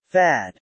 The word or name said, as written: fad